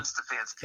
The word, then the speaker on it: Stefanski: You said Stepnoski. It's Kevin Stefanski.